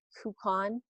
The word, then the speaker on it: Kukhahn: I think the best spot is my Instagram account, which is Angela Kukan, which is spelled K-U-K-H-A-H-N.